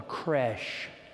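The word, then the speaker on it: crèche: Now, nativity scenes are exhibited throughout the world, and oftentimes we use the French word for crib called a creche. It rhymes with the word fresh.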